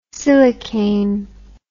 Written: silicane

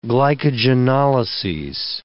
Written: glycogenolyses

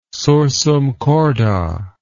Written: sursum corda

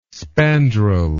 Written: spandrel

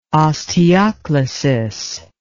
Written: osteoclasis

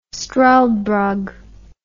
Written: struldbrug

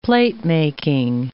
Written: platemaking

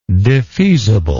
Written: defeasibly

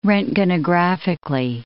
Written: roentgenographically

